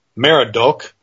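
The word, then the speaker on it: Merodach: Now, according to the Encyclopedia Britannica, Marduk, and notice that name, Marduk, because here in verse 2 we're talking about something pronounced as Merodok.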